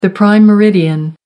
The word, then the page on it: prime meridian: American English